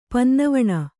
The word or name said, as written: ಪನ್ನವಣ